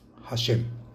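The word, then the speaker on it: Hashem (השם): y deberá meditar el nombre de Hei Shin Mem, que se pronuncia Hashem.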